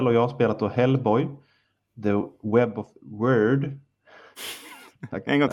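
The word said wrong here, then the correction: Wyrd: Jag kan fortfarande inte uttala det riktigt. The Word. Weird. Mm. Ja, eh, W-Y-R-D.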